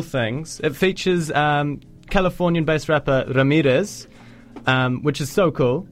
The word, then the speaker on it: Ramirez: Well, firstly, kudos on the pronunciation of Ramirez. – Yeah. – No one's ever said it better. Ramirez. – Bit of roll of the R's, yeah.